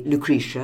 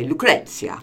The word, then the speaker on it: Lucrezia: Well, if you want to be English, you would say Lucretia, and if you want to be Italian, you'd say Lucrezia.